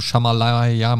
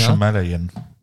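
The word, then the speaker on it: Shyamalan: Solange man eben jetzt nicht erwartet, ich möchte jetzt den, den krassen hier im Shamalaya, Malamaya, Himalaya-Twist äh, am Ende haben. – Ja? Shamalayan. – Genau. – ähm, ich, ich, hasse diesen Namen. Also bewusst natürlich falsch ausgesprochen, aber ich hätte es richtig auch wieder nicht hingekriegt.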